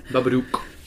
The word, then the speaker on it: Babadook: Babadook.